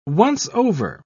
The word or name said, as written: once-over